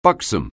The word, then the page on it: buxom: anglais